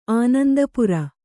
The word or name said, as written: ಆನಂದಪುರ